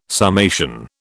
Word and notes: summation: British English

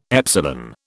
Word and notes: epsilon: British English

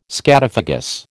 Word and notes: scatophagous: American English

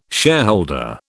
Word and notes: shareholder: British English

